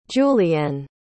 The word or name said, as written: guiliaine